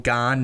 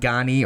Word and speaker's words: Gane: It is Ciryl Gane, not Gani or Gane, as I've said before.